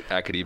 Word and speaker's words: Acadie: And especially that season, I was lucky enough to go to the Memorial Cup game where they played Athlete Bathurst. Is that how you say it? – Acadie Bathurst.